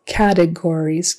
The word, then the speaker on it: categories: Categories, so syllable stress on the first syllable, categories. Not categories, categories. Whatsoever.